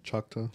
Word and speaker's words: Choctaw: Choctaw.